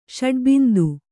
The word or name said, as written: ಷಡ್ಬಿಂದು